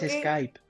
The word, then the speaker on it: Skype: Eh, podemos es Skype, aprende a pronunciar Skype. – Kipe. Skype.